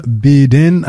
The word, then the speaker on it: Beaden: And that was by Anna Beadin. I believe that's how you pronounce her last name. B-E-A-D-E-N.